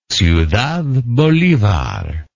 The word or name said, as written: ciudad bolivar